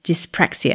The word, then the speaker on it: dyspraxia: She helps families with neurodiverse children, such as those who have autism, ADHD, ADD, dyslexia, and others such as dyspraxia.